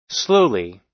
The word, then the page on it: slowly: American English